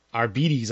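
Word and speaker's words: Arbites: And Arbetes, I guess, is the correct pronunciation.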